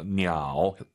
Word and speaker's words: 鸟: 삼성이 마지막 글자만 올리지 마세요. 냐오. 하지만 사실은 보통 량지냐오. 더 중국스럽게 발음하시려면 반삼성으로 딱 끝냅시다.